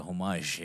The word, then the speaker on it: homage: Like an homage? – Like a homage, yes, man. – Homage? – Yes. – H is silent.